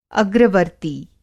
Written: अग्रवर्ती

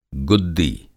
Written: गुद्दी

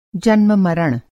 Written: जन्म-मरण

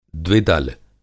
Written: द्विदल

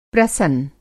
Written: प्रवसन

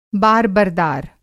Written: बार-बरदार

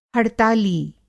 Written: हड़ताली

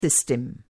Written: सिस्टम

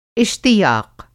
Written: इश्तियाक़

